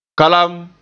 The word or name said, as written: kalam